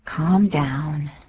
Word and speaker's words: calm: Calm down. Calm down. – Calm down. 就是那个 calm, 那个 L 不发音，可不要念 calm。啊，念 calm 或是 calm 都不要舌头卷起来，那个 L 就当作没有见到一样。